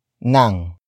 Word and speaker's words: ng: The next word is nang. Nang. Nang. Nang is spelled as ng.